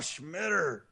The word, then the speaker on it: Schmitter: Ah, Schmitter. We hardly knew ye. – His name rhymes with shitter.